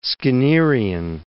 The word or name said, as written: Skinnerian